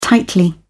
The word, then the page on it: tightly: British English